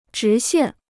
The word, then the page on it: 直线: zhí xiàn